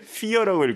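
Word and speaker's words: fire: 사주라는 곡인데 이것도 스펠링이 파이어입니다. 파이어. 파이어인데 피어라고 읽고 사예요.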